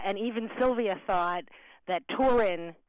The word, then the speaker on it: Turin: It's not Torino for us. It's Turin.